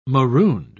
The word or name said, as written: marooned